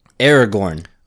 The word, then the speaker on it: Aragorn: Aragorn.